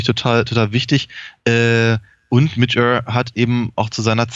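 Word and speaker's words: Midge Ure: Ähm, also ich fand, ich habe mich, hab mich sehr gefreut, aber ich glaube, ich, ich, bin mir ich bin relativ sicher, dass Mitch Err ausgesprochen wird.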